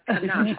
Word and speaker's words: Canuck: No, it's C-A-N-U-C-K, so it rhymes with suck. Yeah. Or. – I just bleeped her. Canuck.